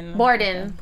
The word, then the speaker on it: Borden: Maddie with Lizzie Borden. Am I saying that right? – Borden. – Yes.